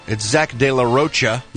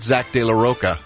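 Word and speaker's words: Zack de la Rocha: It's Zach De La Rocha. – Yes. – Not Zach De La Roca.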